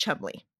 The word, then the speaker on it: Cholmondeley: So I think Chummy is from her second middle name, which is spelled Cholmondeley but pronounced Chumley because okay.